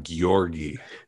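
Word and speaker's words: Györgyi: It's the one with the G-Y-O-R-G-I. – Georgi. That's how I've been saying it. – Yeah, Zent Georgi, because you did some investigations to find out what is prana.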